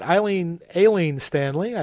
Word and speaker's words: Aileen Stanley: Before that, Aileen Stanley, I should say. That's the correct way to pronounce her name.